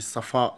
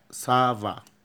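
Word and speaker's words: ça va: كومون سافا، كومون سافا، مش صفاء لا، سافا. كومون سافا، كومون سافا يعني عامل ايه، اخبارك ايه. سافا بيان ميرسي، سافا بيان ميرسي. يرد يقول سافا بيان ميرسي. ولو قال سافا بس كويس، سافا.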